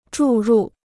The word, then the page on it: 注入: zhù rù